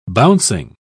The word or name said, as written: bouncing